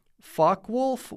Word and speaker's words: Focke-Wulf: This group is called Focke-Wulf 190, and that's Focke, being very nervous pronouncing that name.